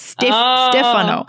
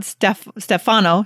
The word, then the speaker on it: Stefano: It's the Italian pronunciation. It's Stefano. It's not Stefano.